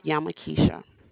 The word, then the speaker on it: Yamakesha: My name is Yamakisha.